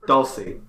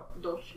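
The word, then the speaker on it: Dulcy: Dulce. That's how it was pronounced in the show. – Dulce. – Is it spelled like Dulce? I don't remember. – It's D-U-L-C-Y.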